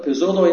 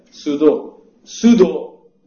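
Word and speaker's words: pseudo: من گفتم پزودو، یه بنده خدا تذکر داد که این پزودو نیست، زودو تلفظی ولی پی داره، سودو من، سودو تو انگلیسی میدونی چیه دیگه، یه کلمه اینجوری بود اگه اشتباه نکنه اسپلش، حالا شاید نمیشه او بوده چی.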